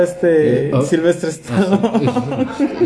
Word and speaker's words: Stanko: siendo este Steve Stacon el ganador del primer Mister Universo. – Steve Stacon, ¿no? – Stacon, Stacon. Stanco. Bueno, depende como la pronunciación y el acento. Steve Stanko.